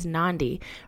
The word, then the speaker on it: Nandi: My name is Nandi, rhymes with Gandhi, and I am a mindfulness-based life coach.